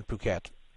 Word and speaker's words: Phuket: It's spelled P-H-U-K-E-T, spelled fuck it. P-H-U-K-E-T, but it's pronounced Phuket.